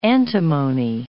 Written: antimony

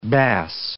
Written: bass

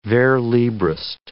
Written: vers-librist

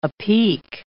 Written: apeak

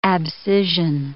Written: abscission

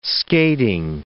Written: skating

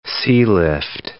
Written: sealift

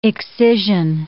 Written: excision

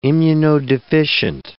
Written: immunodeficient

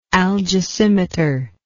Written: algesimeter

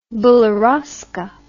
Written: beriozka

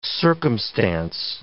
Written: circumstance

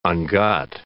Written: ungot

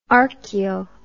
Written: archaeo

